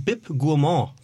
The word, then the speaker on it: Bib Gourmand: Nichtsdestotrotz hat deine gute Botschaft einen Bib Gourmand bekommen.